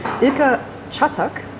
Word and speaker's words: Ilker Çatak: Vor mir sitzt Ilker Çatak. Ich hoffe, es ist richtig ausgesprochen.